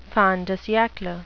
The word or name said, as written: fin de siecle